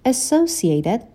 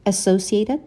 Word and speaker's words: associated: Associated with the behavior. که ربط داشته باشه با اون رفتار. Associated دوتا تلفظ داره. میتونیم بگیم associated یا associated. فرقی نمی کنه.